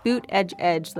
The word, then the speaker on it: Buttigieg: Boot edge edge, like a boot on your foot, edge at the edge of a table.